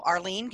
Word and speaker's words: Arlene: So Arlene Gale, A-R-L-E-N-E-G-A-L-E.